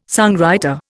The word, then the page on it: songwriter: Indian